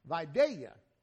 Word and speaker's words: Vidalia: And Vidalia, pronounced the proper way, onions.